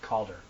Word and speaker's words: Calder: I think it was Calder. – Calder. – I'm sorry, did I say it wrong? – Yes, you did. – Say it again. – Calder. Like a cauldron.